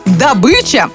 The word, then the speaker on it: добыча: Если опираться на правила русского языка, «добыча» звучит именно как «добыча». В орфоэпическом словаре и словаре ударений «добыча» приводится как единственно верный вариант. По мнению языковедов, у человека, который хочет говорить правильно, только один путь – к ударению на «ы».